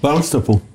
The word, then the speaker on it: Barnstaple: Is that how you say it? Barnstable or Barnstaple? – Barnstaple.